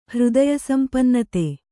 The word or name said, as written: ಹೃದಯಸಂಪನ್ನತೆ